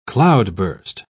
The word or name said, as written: cloudburst